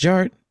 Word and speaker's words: DJarTT: DJ Art, with two T's for a double dose of that tink-tink. The D is silent, so it's just Jart.